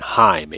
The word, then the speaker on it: Heim: That's Kuno, C-O-N-O, Schweier, S-C-H-W-E-Y-E-R, and Heim, H-E-I-M. Heim means home in German. Again, notice the E-I pronounced I.